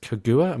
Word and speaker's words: Kaguya: And then Gumama says, Akami is also hugely reminiscent of the tale of the Princess Kaguya.